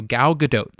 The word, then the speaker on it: Gal Gadot: So Gal Gadot, that is the proper way to say her name.